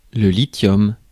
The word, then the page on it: lithium: American English; /ˈlɪθiəm/